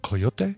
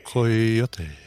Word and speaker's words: Coyotes: It is pronounced Coyote. – Coyote? – Coyote. – Coyote machete. – Coyote. – Wonderful.